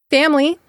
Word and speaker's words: family: Family, same thing. Family has two syllables. There's a silent I in the middle, family.